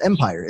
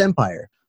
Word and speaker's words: Empyre: Cause I'm like, oh yeah, it's Empyre. It's Empyre.